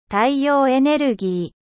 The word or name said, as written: energie solaire